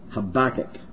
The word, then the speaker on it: Habakkuk: How do you say that? Habakkuk. Sounds like tobacco to me, but and I don't think that should have any place in Scripture.